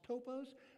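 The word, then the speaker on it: topos (τόπος): In the Greek, it's pronounced topos, but it really means it's a physical location.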